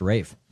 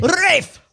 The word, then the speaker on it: Ralph: I just want to know how you get R-A-L-P-H to be pronounced Rafe. – British. – Right. – Rafe!